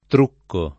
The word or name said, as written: truccare